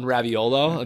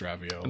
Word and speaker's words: raviolo: Just like when they give you like one raviolo and they're like, come on. I'm sure it's great. – Love that you correctly pronounced raviolo.